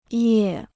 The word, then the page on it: 业: yè